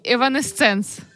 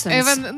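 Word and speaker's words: Evanescence: В неї висіли плакати таких гуртів, як Нірвана і Еванесценс. – Еванесенс.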